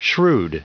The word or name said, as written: shrewd